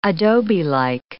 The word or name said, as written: adobelike